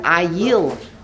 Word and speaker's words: Ayil: And the only difference is the pronunciation, the vowel. Ayil versus Ayol. Yitzchak was replaced with an Ayil.